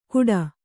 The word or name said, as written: ಕುಡ